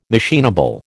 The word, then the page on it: machinable: American English